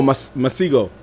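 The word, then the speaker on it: Masego: Masco? You ever never heard of him? Masco. I think that's how you say it. – It's right there. This dude. – Oh, Masigo.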